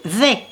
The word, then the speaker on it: Vay: Marie V, ça s'écrit V-A-Y, voilà.